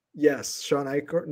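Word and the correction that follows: Achor: I'm going to mispronounce it. Acker. It's A-C-H-O. – Sean Acker. Sean Acker. – Acker. There you go.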